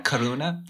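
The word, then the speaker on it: karuna (करुणा): Is karuna compassion? Am I saying that right? – Yeah, karuna. Yeah.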